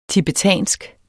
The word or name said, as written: tibetansk